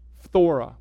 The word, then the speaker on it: phthora: Now, that word corruption is the Greek word phthora. Try saying that one. Phthora. Like, here's how it's spelled. P-H-T-H-O-R-A, phthora.